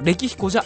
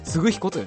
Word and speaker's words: 歴彦: まずは著者のご紹介なんですけども、角川嗣彦さん、有名な方ですね。角川グループホールディングスの代表取締役会長兼 CEO を務めてらっしゃる方で、お父さんがね、角川書店の創業者でもあると。歴彦じゃありません。嗣彦と読みます。歴史の歴に彦なんですね。